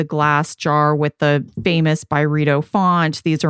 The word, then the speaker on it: Byredo: And it's actually a candle collab with Ben Gorham himself, who's the founder of Byredo, if you guys are not familiar. And by the way, Carlene, we've been pronouncing it Byredo for how long? It's actually Byredo.